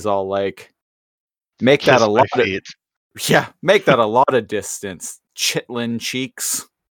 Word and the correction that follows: chitin: Oh, chitin cheeks. – And I'm probably just pronouncing it wrong. – Yeah, chitin is like the hard exoskeleton of an insect. – Yep. Oh, I knew what it was. I just apparently didn't know how to pronounce the word.